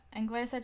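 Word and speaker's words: Anglesey: It's not pronounced Ang. – I know, but that's how it's spelled. – Anglisa to Aberdeen.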